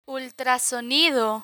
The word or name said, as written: ultrasonido